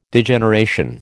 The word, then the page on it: Degeneration: American English